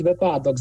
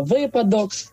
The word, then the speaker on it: випадок: Ну, скажімо, говорить випадок замість літературного випадок, новий замість літературного новий.